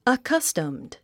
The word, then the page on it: accustomed: əkʌ́stəmd; アカァスタムド